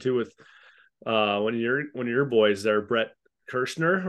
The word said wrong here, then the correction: Kreischer: or whatever. What's his name? – Brett Kreischer. – Yeah, Brett Kreischer. I really butchered his name.